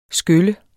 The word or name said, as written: skylle